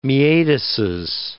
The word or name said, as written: meatuses